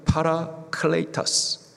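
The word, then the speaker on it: 파라클레이타스 (παράκλητος): 보혜사, 헬라식 발음으로는 파라클레이타스, 파라 함께 하시다, 클레이타스 도우시는 분, 파라 훅 눌러가지고 파라클레이타스 하면은 함께 하시며 도우시는 분, 그분이 바로 보혜사 성령님이십니다.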